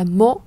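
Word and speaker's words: mot: chẳng hạn như là bây giờ chúng ta đọc là mộ và nó viết là à, m o t và chúng ta có một từ nữa là mô m a u x chúng ta cũng đọc là mô đọc như nhau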